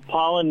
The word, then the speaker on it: Paullin: Paulin, not as in the bee's knees. – Okay. – It's spelled P-A-U-L-L-I-N.